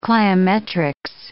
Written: cliometrics

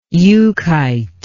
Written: euchite

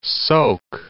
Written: Peterborough, Soke of

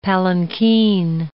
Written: palanquin

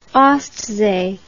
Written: ostsee

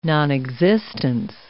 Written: nonexistence

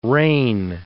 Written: rein

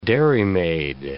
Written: dairymaid

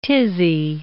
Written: tizzy

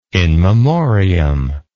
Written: in memoriam

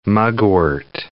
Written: mugwort

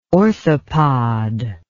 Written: orthopod

